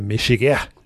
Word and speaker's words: Michigan: If that's not fancy enough, you can go full out and get a Michigan, pronounced a Michigan, but almost in one syllable.